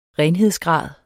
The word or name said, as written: renhedsgrad